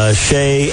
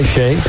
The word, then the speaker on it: Shai: The senior cheerleaders were Nicole Brunswick, Emerson Guggenbiller, Shay Ann Rodriguez. Did I say that right? – I believe so. – S-H-A-I, Shay.